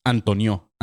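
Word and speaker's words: António: Y en esta, un vato llamado Antón, es Antonio, pero con, con acento en la primera voz. Entonces es Antonio, Antonio Guterres, güey.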